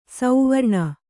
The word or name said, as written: ಸುವರ್ಣ